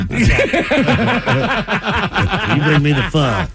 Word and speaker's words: pho: Pho. – Pho. – Pho. – Pho. – Pho. – Pho. Pho. Pronounced as the Vietnamese themselves do it, pho.